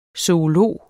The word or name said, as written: zoolog